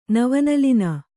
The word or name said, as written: ನವನಲಿನ